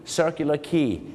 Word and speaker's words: Circular Quay: ไปบอกควายแอท่าเรือที่รับผู้โดยสารเนี่ยนะครับเขาจะออกเสียงว่าคีครับผมถูกต้องเลยครับอย่างเช่นไปที่ออสเตรเลียที่ซิดนีย์ circular quay นะฮะไปที่สิงคโปร์ครับคลาร์กคี